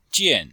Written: jian4

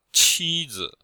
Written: qi1zi5

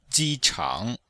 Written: ji1chang3

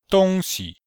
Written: dong1xi5

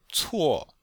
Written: cuo4

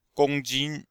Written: gong1jin1

